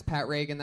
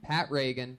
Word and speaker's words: Pat Regan: That's Pat Reagan. That's spelled P-A-T-R-E-G-A-N. Not like Ronald Reagan. It's announced like Ronald Reagan. Pat Reagan, but there's no A in that. Follow him on Twitter at Patty Reagan. P-A-T-T-Y-R-E-G-A-N.